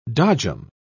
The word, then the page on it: dodgem: British English